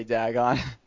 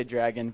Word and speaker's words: Dagon: Some people pronounce it Dagon, whatever they say. I say Dagon. Sometimes they say dragon, but that's not what it's called.